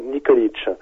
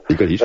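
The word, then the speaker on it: Nikolić: Non, c'est Tomislav Nikolic. – Nikolic, c'est ça.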